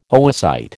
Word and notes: oocyte: American English

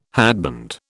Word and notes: hatband: British English

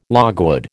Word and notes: logwood: American English

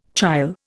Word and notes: chyle: Indian

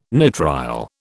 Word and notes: nitrile: British English

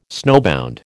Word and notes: snowbound: American English

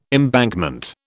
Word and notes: embankment: British English

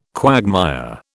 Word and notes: quagmire: British English

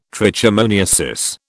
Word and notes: trichomoniasis: British English

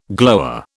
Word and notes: glower: British English

